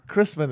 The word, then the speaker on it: Christman: Which do you prefer? – Christman is generally how it's pronounced.